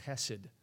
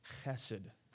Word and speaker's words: chesed: There's this in the Hebrew, I'm kind of a Bible nerd, and in the Hebrew it's the word, it's pronounced chesed. There's this guttural thing to it in the Hebrew, it's kind of cool, but chesed.